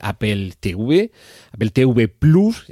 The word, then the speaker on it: Apple TV Plus: ya sé que les gusta que sea TV Plus, pero es que la T y la V en español se pronuncian así, y Plus, o un signo más, es que en español, si nos referimos a la pronunciación del latín, se pronuncia así, Apple TV Plus.